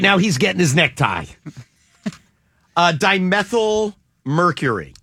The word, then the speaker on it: dimethyl mercury: Does anybody know what dimethyl mercury is? Dimethyl mercury. And I hope I'm saying it right. D-I-M-E-T-H-Y-L.